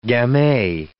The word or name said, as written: gamay